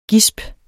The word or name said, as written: GISPENE